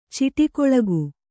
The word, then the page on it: ಚಿಟಕೊಳಗು: ciṭakoḷagu